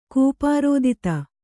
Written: ಕೂಪಾರೋದಿತ

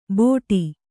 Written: ಬೋಡಿ

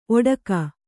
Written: ಓಡಿಕೆ

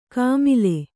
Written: ಕಾಮಿಲೆ

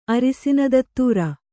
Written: ಅರಸಿನ ದತ್ತೂರ

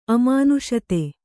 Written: ಅಮಾನುಷತೆ

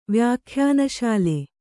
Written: ವ್ಯಾಖ್ಯಾನಶಾಲೆ

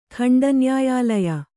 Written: ಖಂಡನ್ಯಾಯಾಲಯ

